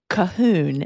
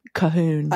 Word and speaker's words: Colquhoun: My family, I'm a Colquhoun. Is that how you pronounce it? – Nice. – C-O-L-Q-U. I can't. H-O something. That's not how we spell it here. We spell it Calhoun.